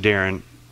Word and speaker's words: Darian: I pronounce it Darren, phonetically, Darian.